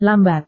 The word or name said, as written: lambat